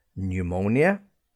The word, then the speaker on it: pneumonia: Other words where P is silent would include words like pneumonia, begins with the letter P, sounds like you have at the beginning N-E-W, new, yeah, but it's P and it's a silent P.